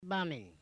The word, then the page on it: baaminy: Noongar